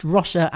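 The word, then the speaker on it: Róise: No, she says it's pronounced Rosha as in John.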